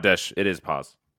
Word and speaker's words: Paz: was it Paz? – That's one of them. – Is how you say it? – Paz and Tesh.